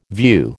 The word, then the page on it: view: American English